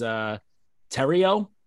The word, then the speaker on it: Terrio: I'm not like that sorry about it, since we're bad with names anyway, but apparently we've been pronouncing the people's goalie's last name incorrectly this whole time. I've been big on the Thoreau pronunciation. Apparently it is Terrio.